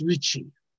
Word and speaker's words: Ricci: If you go to the Ponte Vecchio in Venice, that's also C-C-H-I. On the other hand, I was confused because this guy, in Italian, that's pronounced Ricci.